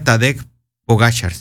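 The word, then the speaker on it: Tadej Pogačar: Se pronuncia Tadej Pogacar, ¿sí? Pogacar. Ya sé, Tadej Pogacar. Es el ciclista.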